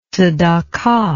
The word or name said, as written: zedakah